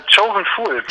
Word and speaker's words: Chosen Fool: Ich würde Choose Fool sagen, oder wie würdest du das aussprechen? – Chosen Fool. – Chosen Fool, okay.